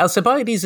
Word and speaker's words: Alcibiades: I'm not quite sure how you pronounce it, but it's the student of Socrates, Alcibiades, that's how you pronounce it. Yes.